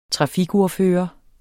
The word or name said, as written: trafikordfører